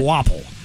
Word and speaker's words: Kvapil: It's spelled K-V-A-P-I-L, but it's pronounced Quapple. – Quapple. – Yeah. Quapple.